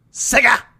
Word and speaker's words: Sega: Sega.